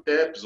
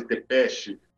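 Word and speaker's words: Țepeș: O Vlad II é o Vlad Dracul, que vocês conhecem, e o Vlad III é o Vlad Tepes, ou Vlad Tepeche, depende do sotaque, depende da região, depende da pronúncia.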